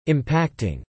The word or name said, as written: impacting